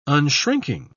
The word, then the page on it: unshrinking: British English